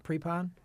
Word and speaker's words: Prepon: His girlfriend is played by Laura Pepon. How do I pronounce her? – Prepon.